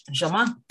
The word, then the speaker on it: Germain: It's French, Germain.